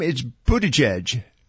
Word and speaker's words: Buttigieg: That's an easy name. It's Buttigieg. – Buttigieg. – Buttigieg. It's very easy.